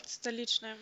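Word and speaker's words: Stolichnaya: Ja, Pepsi tauschte sein Cola nämlich einfach gegen und ich weiß nicht, ob ich, du kannst mal sagen, ob ich das richtig ausspreche, Stolichner? Stolichner, du, du weißt den Stoli halt, ne? – Stalitschner, mhm.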